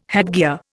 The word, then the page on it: headgear: Indian